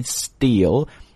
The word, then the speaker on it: steel: steelmaker 就是 steel 和 maker 两个词合在一起， steel 就是我们知道叫钢铁，注意这个词的发音 steel, 两个 E 要发长音的 E,